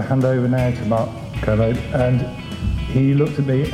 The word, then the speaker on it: Kermode: and I was very conscious that Mark's surname is pronounced Kermode. I knew that because I'd looked it up before, and I knew that you have to think of Kermit. Kermit, Kermode.